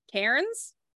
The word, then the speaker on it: Cairns: Cairns. – Yeah, but we don't say R's in Australia. Remember? – Yeah.